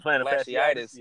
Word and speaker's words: fasciitis: Uh, and then me when I found out I had plantar flashy, flashy, have you pronounce yeah, it, fasciitis.